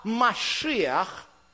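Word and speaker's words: машиах (מָשִׁיחַ): Отсюда слово «машиах», которое часто произносят как «мессия».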